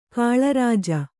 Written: ಕಳ್ಳರುಜು

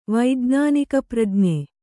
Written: ವೈಜ್ಞಾನಿಕಪ್ರಜ್ಞೆ

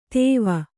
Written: ತೋವು

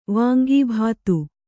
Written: ವಾಂಗಿಭಾತು

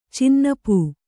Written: ಚೆನ್ನೊಪ್ಪು

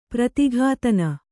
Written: ಪ್ರತಿಘಾತನ

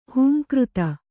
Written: ಹುಂಕೃತಿ

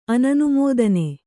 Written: ಅನನುಮೋದನೆ

